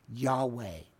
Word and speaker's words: Yahweh: Yahweh, that we hear a lot today, really comes from four letters of the Hebrew language. Yod, He, Wah, He. Yahweh is how we pronounce it.